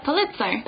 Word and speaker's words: Pulitzer: Yeah, Pulitzer.